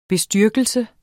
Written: bestyrkelse

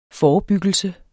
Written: forebyggelse

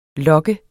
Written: log

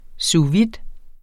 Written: vide

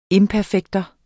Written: IMPERFEKTUMMETS

